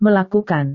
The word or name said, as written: melakukan